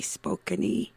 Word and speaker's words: Spokane: Locally, this is Indigenous Peoples Day here in Spokane, the town named after the Spokane tribe, which really, if we said it right, it would be Spokane-y.